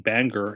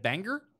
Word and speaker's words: Bangor: Is that how they say it? Bangor? Like, Bangor hardly knew her? I thought it was Bangor. – Maybe it is Bangor.